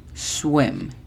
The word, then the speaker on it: swim: La pronunciación fonética de swim es S-U-E-M. Swim.